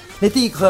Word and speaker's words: Le Tigre: ¿Tiene acento en la E al final o no? – ¿Le Tigre? No, me cagaste. No tiene acento. Pasa que está americanizado.